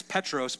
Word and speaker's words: Petros: Peter, it's a Greek name, and when you pronounce it in Greek, it's Petros, but it means rock.